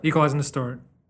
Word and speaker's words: Equalizing X Distort: Equalizing Distort. – Yes. – The X is silent.